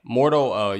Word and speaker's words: Mordo: Or is I saying it right, Mordo? – Yeah, Mordo.